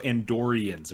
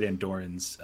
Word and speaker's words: Andorians: That is not correct. They're also Andorians. There's an extra syllable you missed. What? You said Andorans.